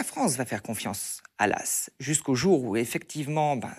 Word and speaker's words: Law: Martial Débrief, écrivain en France, on va faire confiance à un, banquier, LAS. Alors ça s'écrit L-A-W, mais on le, dit bien de cette façon, LAS.